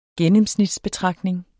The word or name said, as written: gennemsnitsbetragtning